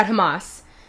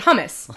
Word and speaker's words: Hamas: In any case, he mispronounced it in several different ways, several different times, but the most notable was that he, at some point, called it hummus.